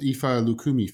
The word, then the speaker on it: Ifa Lukumi: And on another website that you're an initiated priestess and spiritual consultant of the, I don't know how it's pronounced, Ifa Lukumi faith. Is that right? – Yes, and you did very well with your pronunciation.